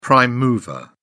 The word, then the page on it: prime mover: British English